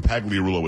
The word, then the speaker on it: Pagliarulo: It's only four syllables when Pagliarulo is five.